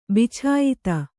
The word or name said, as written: ಬಿಚಾಯಿತ